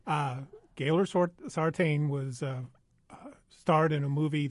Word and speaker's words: Gaylord Sartain: E-A-U-X. – Now, how do you pronounce his real name, the actor? – Well, Gaylord Sartain. – Thank you. – Gaylord Sartain.